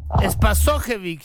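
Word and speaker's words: Spasojević: Es Pasojevic. Se escuchó clarito, ¿ah? ¿Eh? Se escuchó clarito. – El apellido se pronuncia Espasojevic.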